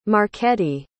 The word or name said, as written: marchetti